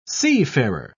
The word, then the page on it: seafarer: British English